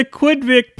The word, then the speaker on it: Quidvic: The Quidvic Plagues, rhymes with Kidpick, is becoming an insidious force in the movie industry.